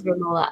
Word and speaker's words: Romola: So I think we did a good job talking about Emma as character and the actress, and I hope I'm saying her name right, Romola. I don't know if it's like Romola or something, but I think it is Romola.